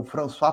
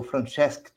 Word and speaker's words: Francesc: o François Tosquelles ou Francesc Tosquelles, depende se você dizer usar a pronúncia catalã, é Francesc, Francesco, sei lá.